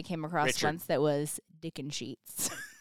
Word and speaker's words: Dickensheets: D-I-C-K-E-N-S-H-E-E-T-S. Dick and Sheets. – Dick and Sheets.